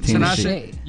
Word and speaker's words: Tinashe: Tinashe.